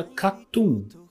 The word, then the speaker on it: KAT-TUN: Quarta semana, quem tomou posse do Oricon Charts é a banda Katun. Se escreve Katun, K-A-T-T-U-N.